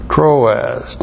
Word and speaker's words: Troas: Troas, T-R long O-A-S, Troas.